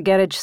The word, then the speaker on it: garage: Come around the back, to the left, not the garage side." – He made garage rhyme with carriage, the stress on the wrong syllable.